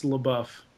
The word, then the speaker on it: LaBeouf: It's Shia LaBeouf is how his name is actually pronounced, but I prefer to call him Shia LaBitch.